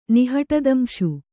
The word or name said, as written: ನಿಹಟದಂಶು